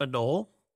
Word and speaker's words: anole: Is it just pronounced anole? – Yeah, that you got it.